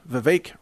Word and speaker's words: Vivek: Rhymes with cake. That's what his press spokesperson told me during an interview. Vivek rhymes with cake.